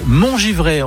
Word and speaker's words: Montgivray: Alors là, il faut m'expliquer parce que Bertrand m'a dit, hors antenne, Laetitia habite Montgivray. On prononce comme ça.